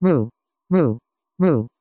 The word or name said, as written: roo